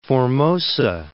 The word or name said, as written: Formosa Strait